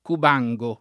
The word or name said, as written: Cubango